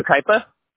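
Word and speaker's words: Yucaipa: Typically, when I just type in like your business name, and then I typed in Yakima, California, it didn't pop up, but I see it in the Yukaipa. – Yukaipa, I'm sorry, Yukaipa, California. – Oh, no problem. You just pronounced it wrong, but you spelled it right, right? – I did.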